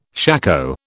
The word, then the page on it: shako: British English